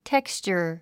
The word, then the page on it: texture: tékstʃə r; テクスチャァ